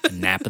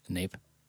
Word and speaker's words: nape: Nape. Nap. I ever pronounce it nap. – Pretty sure it's nape. – Let's look it up. – I'm pretty sure it's nape. – Let's see. – It is nape.